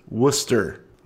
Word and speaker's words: Worcester: Deerfield, New Hampshire is terrifyingly close, and Worcester, Massachusetts is pronounced Worcester. – Worcester, Mass. – What? Worcester, Mass. So there is our lesson for today.